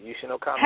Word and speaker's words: Yushin Okami: Yushin Okami.